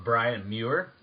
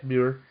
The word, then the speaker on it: Brian Muir: Then my favorite has to be, I'm gonna say it wrong, I'm going to try hard, is Brian Muir. – Yeah, that's right. – There we go, I got it. – Muir.